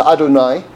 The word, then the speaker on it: Adonai: So on the bottom, it's what we call Adnus. It's pronounced Adonai. But it's Aleph, Dalet, Nun, and Yud.